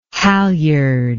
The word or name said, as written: halliard